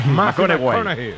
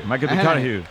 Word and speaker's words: McConaughey: Matthew McConaughey. – Sí, sí, sí, sí. – Hay que ir corrigiendo la pronunciación. – McConaughey. McConaughey.